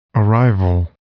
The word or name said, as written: arrival